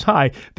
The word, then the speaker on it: Chai: spelled C-H-A-I, means life in Hebrew. High maintenance, but it is pronounced as high.